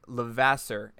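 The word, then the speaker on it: Levasseur: Welcome, Derek Lavasse. Am I pronouncing that last name right? I heard it like 15 times. – No, you literally nailed it. That was perfect.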